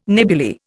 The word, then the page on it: nebuly: Indian